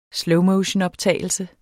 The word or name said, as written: slowmotionoptagelse